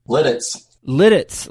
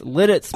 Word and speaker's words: Lititz: Lititz. – Lititz.